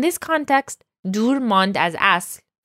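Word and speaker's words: asl (اصل): Next, the word asl. Now, this is one of those Persian words that's a little more difficult to pronounce. The s and the le sound are right next to each other. But again, it's asl.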